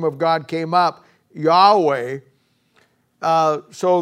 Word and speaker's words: Yahweh: And God's original name translated into the English as YHWH, and the way we pronounce it is Yahweh.